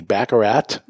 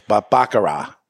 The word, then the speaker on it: Baccarat: There is a T, Baccarat. – Yeah. – But I don't think that's how you say it. It's Baccarat.